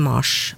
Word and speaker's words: Mars: Og det her kan være forklaringen på at Mars, viktig. Navnet på måneden uttales med kortere A hos oss.